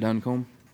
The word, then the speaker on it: Duncombe: It's Matt Duncombe. – What was the last name? Could you spell it, please? – D-U-N C-O-M-B-E.